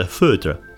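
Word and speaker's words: foeder: A foder, pronounced foder, or fooder I think it might have been. Anyway, it's a Dutch word for a large oak tank where beer is fermented. The French call it a foudre, but they would.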